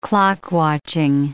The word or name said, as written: clock-watching